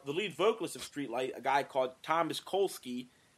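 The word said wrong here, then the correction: Kalnoky: I think that's how you pronounce his name, right? Kolsky? – Thomas Konolke. – Konolke.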